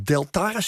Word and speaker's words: Deltares: Floris Boogaert is onderzoeker bij Kennisinstituut Deltares, zeg ik dat goed? – Ja, dat zeg je goed.